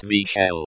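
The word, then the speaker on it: V shell (вышел): Visual, past tense masculine verb, to exit, pronounced V shell.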